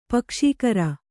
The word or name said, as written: ಪಕ್ಷಕಾರ